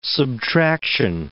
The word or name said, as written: subtraction